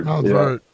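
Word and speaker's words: Montverde: The Gonzaga coach left to take the, is it Monteverde? How do you say that school down in Florida? – Mount Verde.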